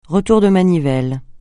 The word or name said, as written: retour de manivelle